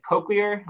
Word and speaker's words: cochlear: So like uh, with the is it cochlear?